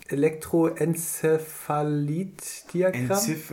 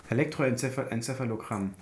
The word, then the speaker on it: Elektroenzephalogramm: Dieses EEG äh, kannst du auch aussprechen: Elektroencephalit-Diagramm. Enzephalogramm. Elektroenzephalogramm